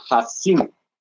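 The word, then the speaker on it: Haazinu: La Parachá se llama Hatsinu.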